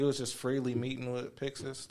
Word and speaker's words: Pixis: Pixish. – Pixis. – Yeah. – Yep. – Yeah, that's how they say it in Japanese.